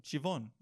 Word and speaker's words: Siobhan: With a V. – Where's the V? Is the BH make a V noise? – I don't know. Apparently. – Bug. – Siobhan. Siobhan. Oh, Siobhan. That's how you say it.